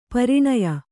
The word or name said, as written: ಪರಿಣಯ